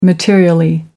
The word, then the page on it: materially: American English